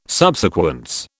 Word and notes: subsequence: British English

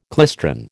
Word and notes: klystron: American English

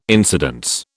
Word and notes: incidence: British English

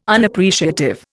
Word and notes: Unappreciative: Indian

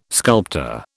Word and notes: sculptor: British English

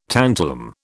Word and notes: tantalum: British English

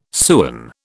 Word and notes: sewen: British English

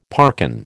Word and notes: parkin: American English

